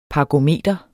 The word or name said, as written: parkometer